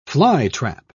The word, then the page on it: flytrap: British English